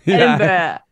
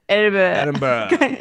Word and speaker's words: Edinburgh: After finding this podcast and listening to every recording on my evening walks during our many lockdowns over here in, okay, Edinburgh. I think that's how you say it.